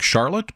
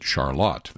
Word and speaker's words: Charlotte: It is spelled like Charlotte, but they pronounce it Charlotte there.